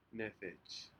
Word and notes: nedej: Aneityum

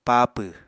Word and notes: paapw: Apatani

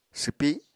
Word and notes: swpi: apatani

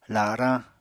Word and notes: larañ: apatani

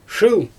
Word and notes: ʃɯl: Xyzyl

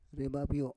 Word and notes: rebabyo: Roon

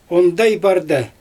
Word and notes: ondaj bardA: Xyzyl

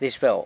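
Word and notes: dizh beu: San Lucas Quiaviní Zapotec

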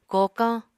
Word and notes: kokañ: Apatani

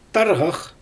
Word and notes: tarɣax: Xyzyl

